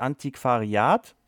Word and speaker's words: Antiquariat: Das heißt tatsächlich Antiquariat. Okay. – So wird es ausgesprochen.